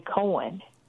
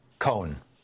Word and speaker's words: Cohn: Then finally McCarthy and Ray, you said Ray Kahn or Ray Cohen? – Cohn. One syllable. – Cohen. Okay. – Cohen. – C-O-H-N. Anyway, people need to look that guy up.